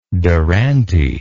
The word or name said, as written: durante